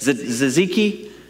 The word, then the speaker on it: tzatziki: And some reason or another, I remembered that a friend of mine, or an actual Greek, gave me a recipe for some tzatziki, whatever the heck that stuff's called. How do you say it? Tzatziki? Tzatziki, okay.